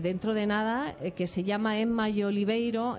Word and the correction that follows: Oliverio: ¿Lo he dicho bien? Oliverio. – Oliverio.